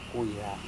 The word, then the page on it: kuira: Serewen